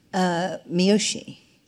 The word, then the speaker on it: Miyoshi: Miyoshi.